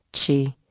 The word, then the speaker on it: qi: In some practices of medicine, energy is known as qi, pronounced qi, and among the most crucial ways we establish qi is by breathing deeply.